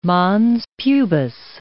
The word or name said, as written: mons pubis